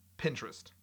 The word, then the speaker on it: Pinterest: It's one word, and the way that I'm saying it makes it sound like it's two words. Pinterest.